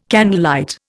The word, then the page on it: candlelight: Indian